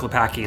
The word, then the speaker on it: Klopacki: Another cool fact about Frank Klopacki is that his name rhymes with Spank Me Wacky.